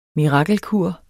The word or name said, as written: mirakelkur